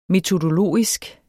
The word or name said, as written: metodologisk